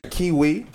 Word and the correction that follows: Kawhi: You know all the syllables in LeBron name, but you gonna mispronounce my man's Kawhi. It's Kawhi, B.